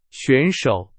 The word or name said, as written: 选手